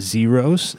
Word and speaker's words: Xeros: And so we are going to close out today's show with a couple songs from the local band Zeros. It's spelled X-E-R-O-S, kind of like Zero on Nightmare Before Christmas.